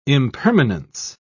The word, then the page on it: impermanence: British English